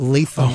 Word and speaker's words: Lapham: Like, so the Burravillians, they pronounce it Lapham Farm. Mm-hmm. But then I had a man from Ireland call in and say that it's Latham Farm, but it's spelt Lapham, L-A-P-H-A-M.